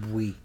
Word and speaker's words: Buie: His last name is Bowie, or Bowie, B-U-I-E. How would you pronounce that? – I'm thinking Bowie.